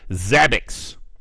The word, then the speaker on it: Zabbix: Zabbix. I'm pretty sure that's how it's pronounced. Z-A-B-B-I-X.